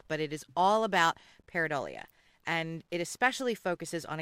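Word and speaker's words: pareidolia: And the only thing I wanted to mention before we go, so you talked so much about Paradalia. – Paradolia. I'm the only one that can pronounce it.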